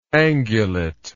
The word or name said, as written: angulateness